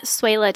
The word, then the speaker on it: Swehla: You can find me on Twitter at Suela Tessa.